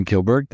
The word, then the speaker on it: Kilberg: Kilberg, thank you for being on our program.